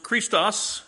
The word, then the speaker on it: Christos: Christ, pronounced Christos in the Greek. By the way, that word K-O-I-N-E, that's my spelling of Koine Greek, common Greek.